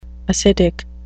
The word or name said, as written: acidic